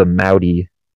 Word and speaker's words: Mudi: Yeah, it's like a mix between it's called the Moudi. – How do you spell that? – M-U-D-I. It's like Audi, but with an M. – Like M as in Mike? – Yeah, M as in Mike. – M-U-D-I dog breed?